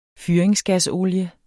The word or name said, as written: fyringsgasolie